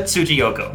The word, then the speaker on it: Tsujiyoko: It's Yuka Tsujiyoko. – Tsujiyoko, okay. Couldn't wrap my head around that one. So as far as Yuka Tsujiyoko, she did a lot of the Fire Emblem series.